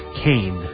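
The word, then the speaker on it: Kean: The campaign had invited most of the prominent Republicans in the area to join him, including former Governor Thomas H., spelled like Keene but pronounced Kane. Don't ask me why.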